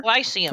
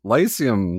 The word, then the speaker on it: Lyceum: Lyceum. – Famous theater? Lyceum, like the lice, like bed bugs and head lice. The Lyceum Theater. What movie, or not movie, what play was playing at the Lyceum Theater when they walked by?